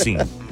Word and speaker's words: Cine: I think it's Cine. – Yeah. – But it's spelled C-I-N-E.